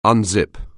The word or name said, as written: unzip